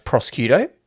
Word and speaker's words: prosciutto: Oh, no, but it's actually pronounced prosciutto.